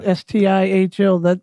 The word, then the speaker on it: Stihl: Isn't Steel? Am I saying that right? – Yeah. – STIHL.